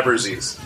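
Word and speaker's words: Abbruzzese: I don't know how you pronounce his name. Abruzia. Abru- Abruzia. Yeah, Abruzzese. So it just has a different feel to it.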